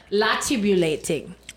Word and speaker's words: latibulating: For the last 2 years, society has been libituating. I'm saying it wrong. – The word is libituating. – Latibulating. Yeah. Latibulate.